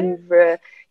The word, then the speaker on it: Louvre: Someone told me I was pronouncing it wrong when I said the Louvre. You say really? – Louvre. Yeah, you say it with like a little French accent.